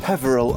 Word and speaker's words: Peveril: Well, we're gonna move down the road from the Britain's Protection to go to somewhere called the Perivale. – The Perivale of the Peak. – Hello there, Update Boy here again. It's Peveril of the Peak, not Perivale, as they mispronounced just then.